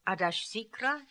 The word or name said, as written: Adashsikra